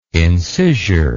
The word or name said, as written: incisure